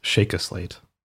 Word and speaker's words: Sheikah Slate: So excuse me, if you're going to be like Seth Everman, it's pronounced Sheikah Slate.